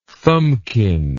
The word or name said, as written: thumbkin